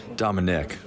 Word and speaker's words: Dominic: All right, we're clubhousing it with Dominique Leone. Did I say that right? Dominic. That's all right.